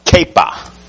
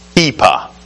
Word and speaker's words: kippah: And so the Hebrew word for this head covering is kippah, pronounced kepa, kepa.